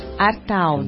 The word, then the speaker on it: Artaud: Artaud, como é que fala? – Escreve-se Artaud. – Artaud. – De mudo, é francês.